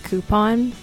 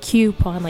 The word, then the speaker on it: coupon: I have to say I'm very impressed with your pronunciation of the word coupon, because every Pittsburgher I know says coupon with it, like coupon, like Cupid.